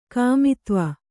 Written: ಕಾಮಿತ್ವ

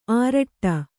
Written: ಆರಟ್ಟ

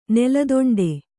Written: ನೆಲದೊಂಡೆ

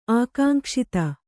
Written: ಆಕಾಂಕ್ಷಿತ

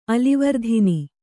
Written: ಅಲಿವರ್ಧಿನಿ

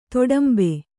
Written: ದಾಡಿಂಬ

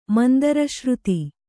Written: ಮಂದರಶ್ರುತಿ

